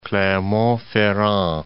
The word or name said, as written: Clermont-Ferrand